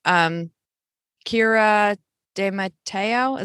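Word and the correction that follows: Keira D'Amato: is that how you say her name? – Oh, she just Kira D'Amato. – Kira D'Amato. – I think it's D'Amato, I think, but I'm not positive either. – That sounds right.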